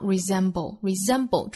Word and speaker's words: resemble: Who we cannot resemble, 中间的这个字母 s 读浊音的 z, resemble, 还要注意最末尾的舌边爆破，爆破音 b, 和舌头音 o 组合在一起发 bull, 所以一定要注意爆破的同时呢，舌尖去抵上齿音 bull, resemble。